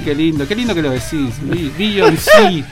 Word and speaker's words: Beyoncé: Beyoncé.